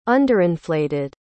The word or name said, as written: underinflated